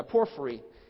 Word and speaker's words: Porphyry: Yeah, Porphyry. This was a man who lived in the late 3rd century A.D. after Christ. And Porphyry was not a Christian. He was a pagan Roman philosopher. And Porphyry, essentially his argument, when he came to the book of Daniel, even though he wasn't a believer, he wrote a book.